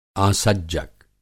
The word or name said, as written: आसज्जक